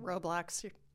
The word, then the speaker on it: Roblox: It's not Roblox, like there's a roadblock in your way on the way to the store. – What is it? – Roblox, like robots and blocks.